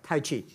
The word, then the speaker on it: Tai Chi: And You also travel with a Tai Chi. Am I saying that right? A trainer. – Trainer. – Tai Chi? – Tai Chi.